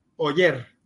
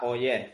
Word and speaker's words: Oller: Se escribe O-L-L-E-R, Oyer. Ah, Oyer, vale. Oyer, ¿vale?